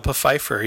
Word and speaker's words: Pfeiffer: Michelle Pfeiffer. Yeah, she's going to be... – Pfeiffer. – That's how you pronounce it, right? – Yeah, makes sense. – The P's not silent.